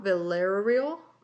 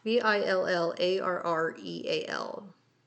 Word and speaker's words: Villarreal: No, I'm sorry, John Villarreal. V I L L A R R E A L. Villarreal. I think that's how you say it.